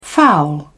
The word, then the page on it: foul: British English